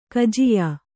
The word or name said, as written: ಕಜಿಯಾ